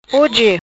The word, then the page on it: Hoje: patua